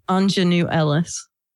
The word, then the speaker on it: Aunjanue: Anjanue is what you call a young female. – Yeah, I know, but it's spelled A-U-N-J-A-N-U-E. – So it's like Anjanue, yeah. – Anjanue Ellis.